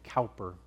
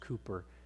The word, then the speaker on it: Cowper: Most people pronounce his name Cowper, but I believe it's actually pronounced Cooper.